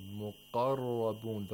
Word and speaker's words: مُقَرَّبُونَ: مُقَرَّبُونَ. The qaf has tafkhim at the second highest level here.